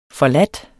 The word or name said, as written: forladt